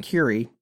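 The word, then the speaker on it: Kure: It's K-U-R-E. I know it sounds like Kerr or whatever, but it's pronounced Curie by all the locals.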